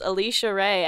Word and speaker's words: Alisya Rae: Do that first before you so, yeah, my name online is Alisya Rae, and it's spelled A-L-I-S-Y-A-R-A-E.